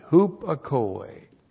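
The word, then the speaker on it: Hupakoi (ὑπακοή): H-U-P-A-K-O, long E. Hupakoi.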